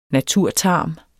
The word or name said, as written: naturtarm